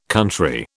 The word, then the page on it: country: British English